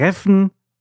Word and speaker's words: Reffen: Selbstverständlich habe ich den Street Food Markt falsch ausgesprochen. Er heißt Reffen und es gibt hier über 30 Essensstände, dazu kleine Läden und Boutiquen und verschiedene Bars.